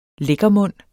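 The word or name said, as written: LÆKKERMUNDENE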